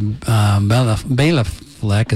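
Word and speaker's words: Béla Fleck: Bela Fleck is, I think, is the way he pronounces his name.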